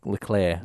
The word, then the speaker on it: Leclerc: Well, Leclerc. I believe Charles Leclerc. – I believe it's pronounced.